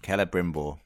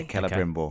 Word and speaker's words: Celebrimbor: Celebrimbor. – Oh, it's Keller, is it? – Keller, yeah. Hard C. – Yeah, Celebrimbor.